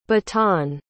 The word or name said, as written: bataan